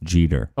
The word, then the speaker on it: Jeter: It's pronounced Jeter. – Oh, thank you. – It's just like Derek Jeter. – Like Derek Jeter, but spelled differently.